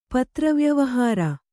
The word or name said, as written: ಪತ್ರವ್ಯವಹಾರ